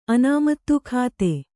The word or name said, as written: ಅನಾಮತ್ತು